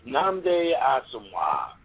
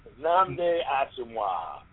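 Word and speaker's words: Nnamdi Asomugha: You're butchering that dude's name. It's Namde Asomugha, okay? I just can't hear you butcher that guy's name. Any longer. Namde Asomugha. – Namde Asomugha.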